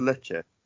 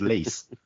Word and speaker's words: Lecce: Where he's from, is it pronounced Lecce? – It is. Well done. – Good, because that will help with the rhyme. – What did you think it was, Lease? – I don't know, mate.